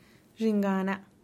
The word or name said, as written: dxingana